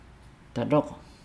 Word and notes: dalogo: Nukuoro

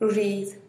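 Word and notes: ruzhiiz: Teotitlán del Valle Zapotec

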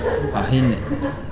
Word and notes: ahine: Nukuoro

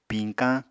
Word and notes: piñkañ: Apatani

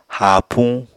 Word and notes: haapuñ: Apatani

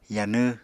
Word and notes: yanw: Apatani

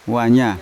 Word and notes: wanya: Yawa

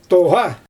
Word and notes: tooɣa: Xyzyl

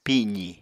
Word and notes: pizi: Apatani

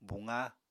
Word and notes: buqa: Apatani

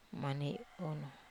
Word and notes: maniai unu: Wamesa

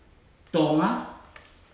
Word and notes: toga: futuna aniwa

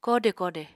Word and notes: koode koode: Apatani

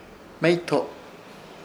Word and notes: Hmeyto: Ishir; Chamacoco

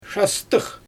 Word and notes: ʃastɯx: Xyzyl

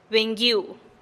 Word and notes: benih guiu: TdVZ

